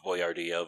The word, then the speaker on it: Boyardee: He even uses a proper pronunciation of Boyardee Boyardee.